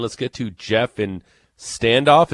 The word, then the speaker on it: Standoff: Is that how you say that? – Yep. – You bet. – It's not like Standoff, and I'm saying it wrong, and people are going to be like correcting me all day. – More like Stand Oof, am I right? – No, it's Standoff.